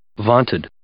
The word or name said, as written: vaunted